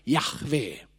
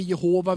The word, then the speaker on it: Jahve: För det, på, på hebreiska så skulle man kanske kunna uttala det Jahve. En del vill kalla det för Jehova, vilket inte är en riktig form, men det är ett sätt att uttala det här, för det är tre konsonanter. Inte så lätt att gissa vad vokalerna är i hebreiska.